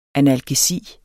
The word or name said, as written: analgesi